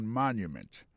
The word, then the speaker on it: monument: Pour le mot monument, il tombe sur le premier syllabe. The Washington Monument. Monument, c'est comme ça on dit monument en anglais.